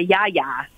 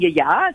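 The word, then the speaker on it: yia yia: they'll either spell it with Y-I-A space Y-I-A, or they'll say ya-ya. Or I always grew up saying ya-ya, so okay. – that's the way I say it.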